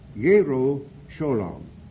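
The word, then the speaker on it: Yerushalayim (ירושלים): A Jew would call it Yerushalayim, the city of peace. You know, the J's in Hebrew and also in European countries generally are pronounced as Y's, not soft, like a soft G as we pronounce it.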